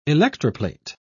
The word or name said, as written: electroplate